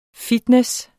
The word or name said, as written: fitness